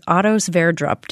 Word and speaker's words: Sverdrup: It's kind of hard to follow that story, but it was Otto Sverdrup.